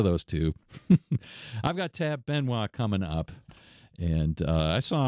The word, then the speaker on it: Benoit: Looks like Tab Benoit, but it's pronounced Benoit.